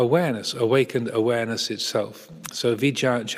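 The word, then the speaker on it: vijjā: It's the same word in the Pali, V-I-J-J-A with a bar on top, vija, a long A.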